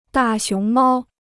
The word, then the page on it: 大熊猫: dà xióng māo